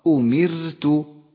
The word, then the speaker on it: أمرت: والآن الراء المرققة في كلمة أمرت، أمرت، لاحظ كيف تلفظ هذه الراء أمرت، رققت لأنها جاءت ساكنة وقبلها حرف مكسور.